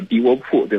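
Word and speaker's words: 地窝堡: 比如说我印象非常深的，包括我也去过好几回的，可能大家也会有点这个印象，就是乌鲁木齐的国际机场叫迪窝铺，对吧？迪窝铺机场，而且经常还大家读成了迪窝堡。 – 对，迪窝铺，它是叫迪窝铺。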